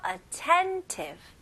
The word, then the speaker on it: attentive: 오케이, 연결하셔서 attentive, attentive. 오케이, 강세가 어디에 들어갈까요? 네, 두 번째 음절에 attentive, attentive.